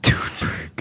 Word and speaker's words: Thunberg: Thunberg. – That's how you pronounce it, technically.